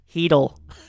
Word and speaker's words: Chytil: Philip Chytil? – Heedle. – Okay. – A silent C.